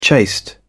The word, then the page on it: chaste: British English